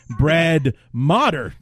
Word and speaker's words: Motter: So I called him Brad Mater the Traitor, and it's actually Brad Motter is his name. That is the way it's pronounced.